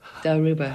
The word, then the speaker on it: Dilruba: And Patty played a new Dilruba, is that how you pronounce it? – Dilruba.